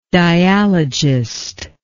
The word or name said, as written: dialogistically